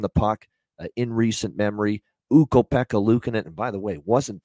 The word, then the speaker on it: Ukko-Pekka Luukkonen: He put it with that perfect Swedish accent on how you pronounce that name, Ukko-Pekka Luukkonen.